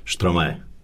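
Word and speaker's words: Stromae: Evo ove godine mi najviše dojmio ovaj Štromaje. Tako se tako izgovara.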